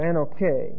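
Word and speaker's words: anoche (ἀνοχή): Forbearance is the word anoche, or anoche, A-N-O-C-H-E, long E.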